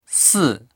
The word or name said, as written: si4